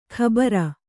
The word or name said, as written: ಖಬರ